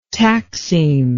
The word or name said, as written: taxeme